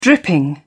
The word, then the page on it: dripping: British English